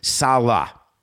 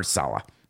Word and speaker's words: Saleh: Saleh, Robert Saleh. I'll get it now. Thank you. Why couldn't you have just said S-A-L-U-H from the beginning?